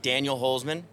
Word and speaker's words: Holzman: Ladies and gentlemen, I'm down here with Daniel Holzman.